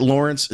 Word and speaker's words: Laurence: If you want to hit me up on Twitter with some thoughts, at Lawrence W. Holmes is where you can find me. My name is spelled L-A-U-R-E-N-C-E.